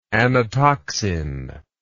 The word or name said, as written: anatoxin